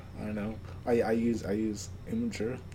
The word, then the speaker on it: Imgur: But I call it Imgur because it's Imgr. It's not, like, there's no E. – Oh. – And there's no A either. So it's like, the official pronunciation is Imgur. But I just think that's a dumb pronunciation.